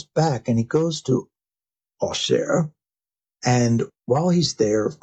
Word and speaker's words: Auxerre: What is Auxerre? Auxerre, right? So that is the French pronunciation.